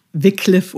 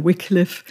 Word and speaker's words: Wycliffe: Also es ist tatsächlich Wycliffe oder Wycliffe ähm, und kommt von dem Herrn, der John Wycliffe hieß.